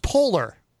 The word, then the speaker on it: Poehler: Amy Poehler. – I believe it's pronounced Polar. – Oh, Polar, yeah.